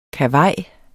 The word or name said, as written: KAVAJERNES